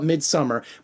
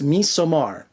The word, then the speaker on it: Midsommar: And I know we call it Midsummer, but I looked up the Swedish kind of pronunciation and the D is silent. At least that's what Google says. So it's Midsommar. Am I right? I hope so. I'm calling it Midsommar.